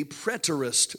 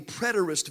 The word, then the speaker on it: preterist: The third view of Revelation is, I don't know if I'm going to pronounce this right, but it's the preterist view. It is P-R-E-T-E-R-I-S-T, the preterist view.